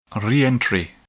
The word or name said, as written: reentry